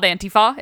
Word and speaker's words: Antifa: I also, right off the top, it's called Antifa. It is pronounced Antifa because it's anti-fascist.